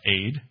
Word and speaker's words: ed: In the Old Testament, the most prevalent root word used for witness is ed, pronounced aid.